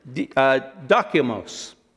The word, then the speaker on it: dokimos: The first word is dokimos, kind of like document.